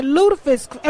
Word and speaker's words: lutefisk: I had lutefisk. Am I saying it right? Lutefisk? – Yeah. – Lutefisk or lutefisk? – Well, and you survived.